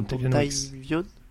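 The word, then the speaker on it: Dontayvion: Dontavion, j'espère que je le dis bien, Dontavion. Oui, c'est ça.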